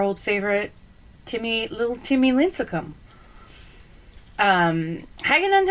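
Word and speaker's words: Hagadone: Did I say it right? Hagedon. – Nailed it. – Hagedon.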